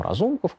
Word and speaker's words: Разумков: Есть, правда, там Разумков, который сейчас вот у них... Или Разум... Как его ударение правильно? – Разум... Разумков. – Разумков, мне кажется, да.